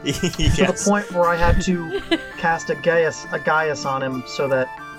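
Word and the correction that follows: geis: It's actually pronounced Gesh, fun fact. – Gesh? – Yeah, Gesh. – Pronounced Gesh? – It's Gaelic.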